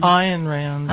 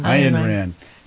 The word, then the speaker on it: Ayn Rand: And we've, in our last segment, we're talking about, I pronounce it wrong all the time. Ayn Rand? – Ayn Rand. – Ayn Rand.